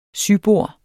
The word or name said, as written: sybord